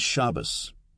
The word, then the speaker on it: Shabbos: An example is Shabbos, Sabbath, with the accent on the first syllable.